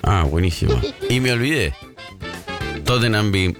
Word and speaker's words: Tottenham: Todos los equipos que terminan con ham, uh-huh, H-A-M, bueno, la H es muda. Ejemplo, Tottenham, Birmingham. Creo que la única excepción es West Ham, pero justamente porque se escribe separado.